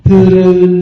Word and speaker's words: thư rư: Chánh văn tiếng Phạn đọc là thư rư, thư rư. Cái chữ đó đọc là ư nha, chứ không có đọc là u được.